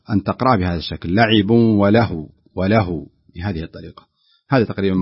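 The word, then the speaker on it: ولهو: هناك ملحظ بسيط في قوله وله، يعني بعض الناس عندما يقف على كلمة وله ولعب وله يشدد في الواو، وهي ليس عليها شدة، لا يوجد على الواو شدة، المفروض أن تنطق بهذا الشكل وله وله، تشدد عندما نصلها، لأن إذا وصلناها يكون تنوين، والتنوين يدغم فيما بعدها وهو حرف الواو، فيكون هناك شدة.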